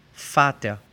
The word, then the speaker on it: Vater: Puede ser la misma que la F en alemán, como en Fata, padre. V-A-T-E-R, Fata.